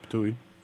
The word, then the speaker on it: Ptuj: So near the town of Varaždin, and actually probably not too far from, I think if I pronounce it right, Ptuj, Slovenia. – Yeah, Ptuj.